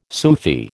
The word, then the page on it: Sufi: American English